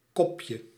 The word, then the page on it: cup: /kʌp/